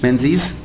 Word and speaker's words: Menzies: Am I pronouncing his name correctly? – Yeah, Menzies. Okay. See, in French, would have dropped the S.